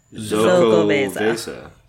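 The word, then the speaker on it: Xocoveza: X in Spanish language is pronounced like Z, so it's Zocovesa.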